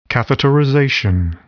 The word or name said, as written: catheterization